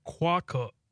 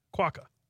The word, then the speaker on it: quokka: Okay, so it's quokka. Quokka. – Okay. – Quokka. That's how you say it.